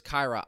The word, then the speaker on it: Kira: I also am pretty sure that I was mispronouncing Kira Lewis Jr.'s name that entire time. I'm pretty sure it's Kyra. I always forget.